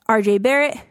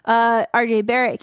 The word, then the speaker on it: RJ Barrett: Key rookies include Zion, Ja, RJ Barrett. Did I say that wrong? – No. – Oh, he looked at me like, okay. RJ Barrett, Cam Reddish, Rui Hachimura.